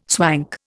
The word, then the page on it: swank: Indian